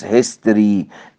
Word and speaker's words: history: وخلي بالكم بقى حضرتك في ثلاث كلمات بنخطئ كثير في نطقهم، منهم اللي هي medicine business history. احيانا نقول ميديسن عشان الاي، بيزنس عشان الاي، لا، او هيستوري عشان الاو، لا، هي مقطعان ضيا سيلابك. ميديسن بيزنس هيستوري، امتى اقول الاي؟